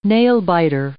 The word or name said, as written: nail biter